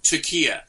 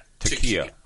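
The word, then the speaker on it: Taqiyya (تقية): How do you say it? – Taqiyya. – Taqiyya.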